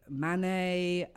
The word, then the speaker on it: Manet: Monet, Manet, Van Gogh.